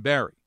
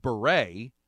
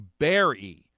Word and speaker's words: Barre: Though Berry was originally pronounced Beret, like the famous French hat, it's morphed into Berry, like the Predator, over the years. Just don't call it Wilkes Bar, or you might find yourself facing a bear, sans beret, in the local bar.